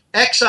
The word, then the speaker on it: Xi: XI is pronounced Xi.